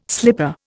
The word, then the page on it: slipper: Indian